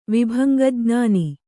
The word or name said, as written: ವಿಭಂಗಜ್ಞಾನ